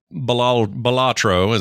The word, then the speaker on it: Balatro: We also played Balatro, is what I've been told is how it's pronounced.